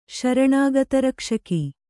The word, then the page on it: ಶರಣಾಗತರಕ್ಷಕ: śaraṇāgata rakṣaka